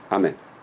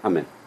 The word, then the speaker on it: Amen: Amen. Don't say amen. That's a snatched amen. You've got to pronounce it with a kamatz. Amen. Amen.